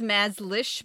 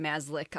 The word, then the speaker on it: Mazlish: It says Mazlish, but I heard her pronounce her name Mazlish on a very old video from the 80s.